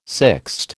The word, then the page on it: sixte: American English